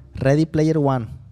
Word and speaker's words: Ready Player One: Ready Player One. Es correcto.